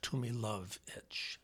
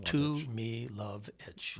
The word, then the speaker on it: Tumilowicz: And I'll say, if it's like too much luggage, you're pretty close. And the accent's on love, Tumilowicz. So Tumilowicz.